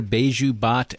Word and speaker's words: Baiju Bhatt: And why are Baiju Bhatt and Vlad Tenev, did I say that right? – You did.